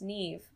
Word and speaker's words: Niamh: It's pronounced Niamh, but it's spelled N-I-A-M-H.